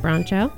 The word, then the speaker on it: Broncho: Is it Bronco? Broncho? There's an H in there, but I have always pronounced it Broncho.